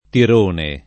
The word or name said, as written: tirone